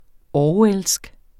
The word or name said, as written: orwellsk